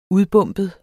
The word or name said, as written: udbombet